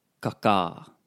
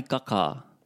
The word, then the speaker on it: kakā: Kakā. – Ai. Spicy food? – Kai kakā. – Kā rawe, kā rawe. Note the stress on the last a. The macron on the last a. Kakā. Mm. Kakā.